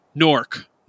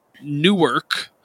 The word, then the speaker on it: Newark: is Nork, not Newark or Newark, which maybe is how you say the same name city in Delaware, but it's Nork. And they very clearly say it like that. It sounds more like N-O-R-K than N-E-W-A-R-K.